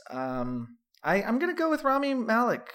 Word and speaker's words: Rami Malek: Kyle Chandler, Bloodline, Rami Malek, I think that's how you say it, right? – Yeah.